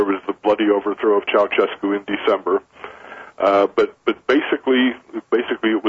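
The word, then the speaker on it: Ceaușescu: Ceausescu in Romania.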